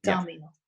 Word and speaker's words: Damhile: It's Darmil, I think. – Darmil. – D-A-M-H-I-L-E. Darmil. Yeah.